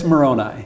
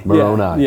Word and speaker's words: Moroni: Yeah, it's Moroni. – Moroni. – Yeah. – Okay, good. – Is the correct way to pronounce it.